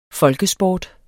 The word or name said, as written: folkesport